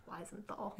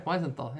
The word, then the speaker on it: Wiesenthal: It's called The Sunflower by a gentleman named Simon Wiesenthal. I'm sorry if I'm not pronouncing that correctly. – Ooh. – What? – Wiesenthal. – Yeah, Wiesenthal, yeah.